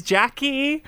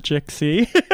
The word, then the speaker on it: Jxcky: That is Jackie, spelled with an X, we should say. – Spelled with an X. – J-X-C-K-Y. – Not Jexy. – Jackie with a silent X.